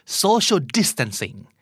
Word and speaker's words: social distancing: social distancing